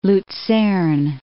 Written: Luzern

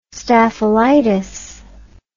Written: staphylitis

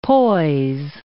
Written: poise

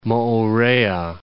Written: Moorea

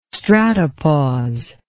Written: stratopause

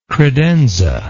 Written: credenza

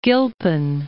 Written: Gilpin

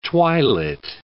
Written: twilit

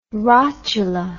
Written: rotula

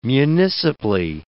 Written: municipally

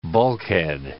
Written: bulkhead

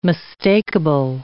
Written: mistakable

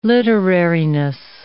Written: literariness

